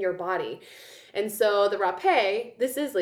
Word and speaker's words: rapé: So anyway, that is rapé. You can look it up. It is spelled like an unfortunate word. It is R-A-P-E, but it has a little slanty line thing over the E.